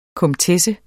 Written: komtesse

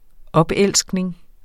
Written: opelskning

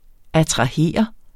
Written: attrahere

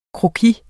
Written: croquis

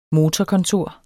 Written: motorkontor

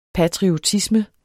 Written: patriotisme

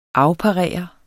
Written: afparere

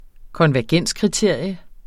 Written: konvergenskriterium

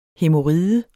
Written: hæmoride

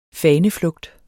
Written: faneflugt

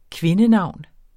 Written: kvindenavn

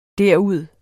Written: derud

